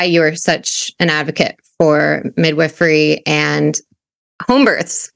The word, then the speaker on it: midwifery: Did I say that right? – Midwifery, you nailed it.